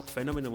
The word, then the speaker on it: Fenómeno: Búscanos en Twitter, en Telegram, en Spotify como Fenómeno Mutante, con acento en la primera O.